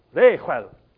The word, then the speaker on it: Reuel: And the priest in that land of Midian was a man named Reuel. It's spelled R-E-U-E-L, Reuel, but it's something that you say it here, and most Westerners don't have it here. And you got Reuel.